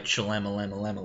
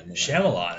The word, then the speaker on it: Shyamalan: Night Shyamalan. – Shyamalan, actually.